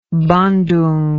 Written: Bandung

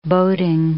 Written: boding

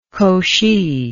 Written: cauchy

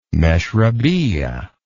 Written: meshrebeeyeh

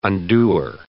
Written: undoer

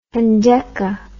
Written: hendeca

